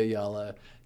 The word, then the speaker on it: Ayala: I always tell people it's like y'all with an A at the beginning and at the end. So, Ayala. So we're here with EJ Ayala, cameo Duran or Doran.